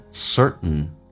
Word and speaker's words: certain: 那么这边有一个单词叫 certain, 当 T 跟 N 在一起的时候，在美式发音经常会发现 T 是不发音的，然后气流阻塞一下变成 certain, certain, certain, 确定的。